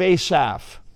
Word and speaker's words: Asaph: It's like PH. It's pronounced like an F though, like phonics. A Psalm of Asaph.